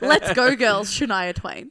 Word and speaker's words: Shania Twain: Shania Twain.